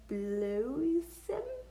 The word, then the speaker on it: bloesem: B-L-O with the macron, S-T-M. And then Dutch. – I'm enjoying watching you try to pronounce this. – In Dutch, you pronounce it bloesem? – It maybe? Sounds like bloated.